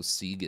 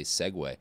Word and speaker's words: segue: Oh, speaking of apprenticeships, that's not a good way to Sieg into an ad. Also, Sieg isn't the right way to say segue.